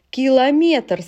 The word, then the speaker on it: километр: Километр, сантиметр, дециметр, миллиметр – это все метр.